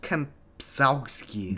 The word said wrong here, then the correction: Kempczinski: That's not Irish. Chris Kempczinski. Kempczinski.